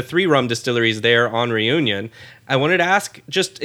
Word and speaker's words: Réunion: And that is that there's a brand out there in Réunion, or how you say it was, Réunion? Did I say that better? La Réunion? I got closer, I think. – Very nice.